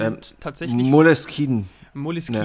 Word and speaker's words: Moleskine: Äh, und, und ähm, das ist eine italienische Marke äh, und da wird ja alles ausgesprochen, wie es geschrieben wird. Deswegen Moleskine.